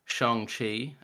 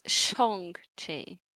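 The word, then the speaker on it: Shang-Chi: No, it's Shong-Chi. – Shong-Chi. – Shong. Yes, you hold it in the back of your throat.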